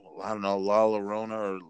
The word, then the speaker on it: La Llorona: La Llorona. Llorona. Llorona. – Okay. – Yeah, I guess that's how you say it. – The double L, it's like you pronounce it like a Y.